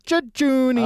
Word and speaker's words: jejuni: Teary Jejuni. – Oh, wow. That sounded like a song. – Which, that's exactly how you pronounce it, so do not question me.